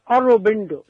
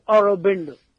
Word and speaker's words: Aurobindo: Aurobindo, Sri Aurobindo.